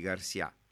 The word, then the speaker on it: Garcia: Di Garcia, come forse è più corretto pronunciarlo. Poi è arrivato ad Ancona, ha avuto un primo periodo in cui obiettivamente ha dovuto guardarsi intorno, capire dove stava, no?